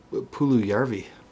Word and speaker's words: Puljujärvi: Oh, it's Jesse Puljujärvi. – Oh, shit. Is that how you say it? – Yeah.